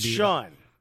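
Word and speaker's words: Sian: It's S-I-A-N. – But the I can be in certain languages. – It's Sean. – Oh, it's that's how it's pronounced. Sean. Okay, maybe.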